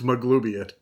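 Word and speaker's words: Maglubiyet: I said it right that time. – Maglubiat is glue in it. – Maglubiat. – You spelled it wrong in your notes. It's Maglubiat.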